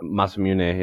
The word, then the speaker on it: Masamune: Masamune or whatever it is. – Yeah, it's probably in Japanese, as I'm sure that's how it's pronounced. Yeah, Masamune, yeah.